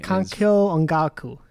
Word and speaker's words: Kankyo Ongaku: Anyway, basically, Kankyo Ongaku.